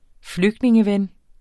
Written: flygtningeven

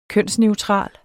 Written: kønsneutral

